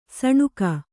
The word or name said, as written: ಸೀನ್ಕೆ